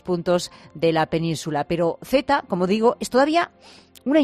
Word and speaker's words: Theta: Se llama Z, aunque se escribe T-H-E-T-A, ¿no?